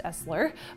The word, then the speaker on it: SSLER: This part of the malware, which is dubbed SSLER, even though it's pronounced Esler, by Talos is persistent through reboots.